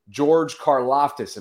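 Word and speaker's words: Karlaftis: George Karloftis. Am I pronouncing that one right? – You hit it on the head.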